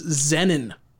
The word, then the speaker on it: Xenin: This is Xenin. I'll spell that for you. X-E-N-I-N. Like Zen, like our good friend Zenny Jardin. But with an N.